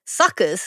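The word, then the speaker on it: PSUKers: Pod saviours, PSUK-ing legends, PSUK-ers, but pronounced suckers, so the P is silent, suckers.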